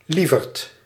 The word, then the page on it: honey: [ˈhʌ.ni]